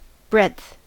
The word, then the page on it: breadth: American English